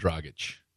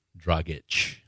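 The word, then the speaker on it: Dragić: D-R-A-G-I-C. Is it my fault because I pronounced it like the American letters that they are, and you jump all over me because it's Strogic.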